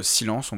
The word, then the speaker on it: Silence: Le titre de ce nouvel album, c'est Silence, on va le prononcer à la française, je le trouve encore plus efficace comme ça.